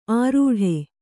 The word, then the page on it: ಆರೂಢೆ: Kannada